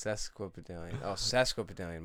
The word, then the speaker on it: Sesquipedalian: Sesquipedalian. My apologies. I said pesk.